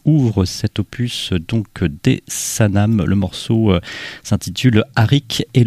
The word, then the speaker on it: Sanam: À l'instant, dans l'asile le plus sûr, pour cette séquence introductive, on a démarré avec le second opus du combo libanais Sanam, comme ça se prononce, S-A-N-A-M,